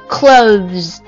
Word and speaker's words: clothes: 它的发音你只要做一个咬舌的那个辅音 clothes 然后舌头滑回去，然后做一个 z 音 clothes。